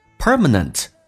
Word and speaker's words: permanent: Permanent 指的是永久永恒的。首先第一个 R 是重度卷舌，整个舌身往回卷，在美式发音当中。 OK, 嘴角往后拉一点点。 R, permanent. 而且 permanent 的最后一个 T is silent, T 不发音，这叫略音，因为后面是以辅音开头。